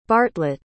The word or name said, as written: bartlett